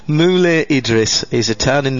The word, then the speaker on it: Moulay: Mule Idris. – Mule Idris. – Mule? – Mm. Yeah, that's spelled M-O-U-L-A-Y. – Mule. – Mulevu by ABBA? – No, M-O-U-L-A-Y.